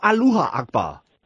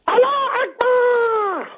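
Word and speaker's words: Allahu Akbar: Is it Allah Akbar or Aloha Akbar? – Allah Akbar!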